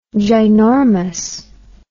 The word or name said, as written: ginormous